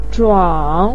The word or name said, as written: zhuang3